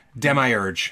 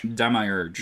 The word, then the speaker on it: Demiurge: Demiurge. – Demiurge.